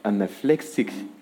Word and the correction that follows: anaphylactic: attack. Is it how you pronounce it? Yeah, anaphylactic attack.